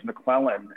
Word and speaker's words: McClellan: But also, my last name is McClellan, and I've had it butchered thousands of times. And I've been waiting to go on stage, and someone's like, all right, please give it up for Trevor McLaren.